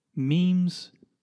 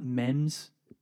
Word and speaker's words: memes: Memes online. First of all, it's pronounced memes, not mems.